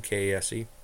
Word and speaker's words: Kase: Well, second period, Kase scores. I believe that's how you say his name, K A S E, uh, from Getzlaf and Vatanen.